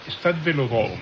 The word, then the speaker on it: Stade Vélodrome: Stade Vélodrome.